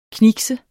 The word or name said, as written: knikse